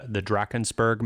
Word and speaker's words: Drakensberg: Is it in the Drakensberg Mountains? – Yeah. – Is that, did I say that correctly? – Yeah.